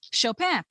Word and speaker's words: Chopin: It says Chopin, pronounced in the French way, as in the name of the pianist Frédéric Chopin.